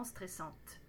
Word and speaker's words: stressante: Pensez à bien prononcer le T au féminin singulier et pluriel. Stressante.